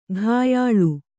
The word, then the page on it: ಘಾಯಾಳು: ghāyāḷu